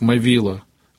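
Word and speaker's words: Movilă: А по-румынски он был румынского происхождения, его фамилия произносилась как Мавила, а на Украине стали называть Могила.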